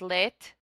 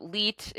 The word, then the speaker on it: Leite: You know, okay, so my last name, Leite, that means milk in Portuguese. – No way. So hold on. – Yeah. It's lit. It's pronounced lit, and it means milk.